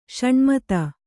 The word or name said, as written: ಷಣ್ಮತ